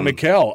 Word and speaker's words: Mikel: Oh, Mikel.